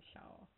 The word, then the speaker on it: Pshaw: Pshaw, she said. Or she doesn't really pronounce the P. She just says shaw.